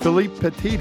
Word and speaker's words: Philippe Petit: Philippe Petit.